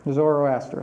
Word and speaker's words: Zoroaster: The Persians were followers of the Zoroaster, however you pronounce it, religion. – Zoroaster.